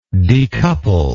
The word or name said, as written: decoupling